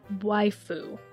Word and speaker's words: Waifu: Progressive Waifu. I think I said it wrong last time. Sorry. So Waifu.